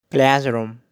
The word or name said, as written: Classroom